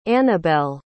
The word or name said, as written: anabelle